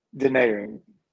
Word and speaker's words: Denaron: Welcome to the show. – Denaron. – Okay, good. Denaron.